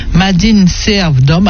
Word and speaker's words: MADINSERVDOM: Alors, pour postuler, envoyez votre CV à Madine Serve d'Homme.